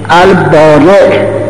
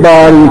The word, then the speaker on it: بارئ: این از تلفظهای غلط فارسی هست، باری تعالی که به خداوند متعال می گن. این همون البارئ هست. بارئ از برعه و آخرش حمزه هست و اسم فاعل بر و برعه البارئ میاد، نه باری.